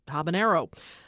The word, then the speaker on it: habanero: Michael Soulis of Portland, Oregon, wrote to say that we mispronounced habanero. It's actually just habanero. Unlike jalapeno, there is no squiggly line over the N.